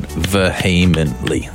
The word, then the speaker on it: vehemently: It was very close to perfect. There's just an extra syllable in it. Vehemently. It's four syllables.